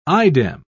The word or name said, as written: idem